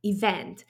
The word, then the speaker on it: event: W angielskim słówku jest akcentowana druga sylaba. Event.